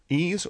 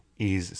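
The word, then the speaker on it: Ys: As many of you may know and or are guilty of, it is often mispronounced. – Oh, man, I was always wise. As a kid, that's what we said.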